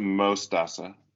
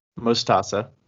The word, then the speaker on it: Mostaza: Mostaza, is that how you say it? – Yeah, so you pronounce it Mostaza. – Mostaza. – Which means mustard in Spanish.